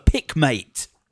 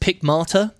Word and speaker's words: Pickmate: Well, I'm talking, of course, about the Pickmate. – I think it's pronounced Pickmata. – It is Pickmata. – It's like the German drink.